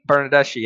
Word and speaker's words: Bernardeschi: And then how do you pronounce that? Bernarducci? – Frederico. – Bernardeschi? – Bernardeschi, yeah.